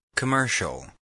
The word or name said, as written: commercial